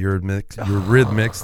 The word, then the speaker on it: Eurythmics: Number 6, Sweet Dreams Are Made of This by the Eurythmics. There's one more syllable in that than I thought.